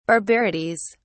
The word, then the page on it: barbarities: British English